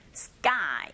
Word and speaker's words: sky: sky라기보다는 s 소리와 그 사운드가 만났죠. 이런 경우에 두 번째 자음의 소리가 된소리 현상이 일어나기 때문에 sky 이렇게 발음하시면 되고요.